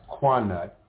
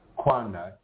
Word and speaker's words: Connacht: Irish mythology tells of two famous beasts, the white-horned bull, pronounced Quanut, C-O-N-N-A-C-H-T, Quanut, and the brown bull of Ulster, U-L-S-T-E-R.